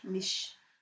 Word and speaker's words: Misch: Then we had Tom Minch, M-I-S-C-H. How would you say that? M-I-S-C-H? – Mish.